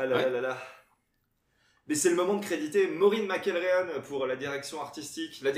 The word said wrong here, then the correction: McElheron: Il y a une nana qui s'appelle, je l'ai mal prononcé d'ailleurs, Maureen McElheron, oh.